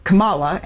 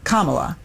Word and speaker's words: Kamala: You said Kamala, and it's Kamala.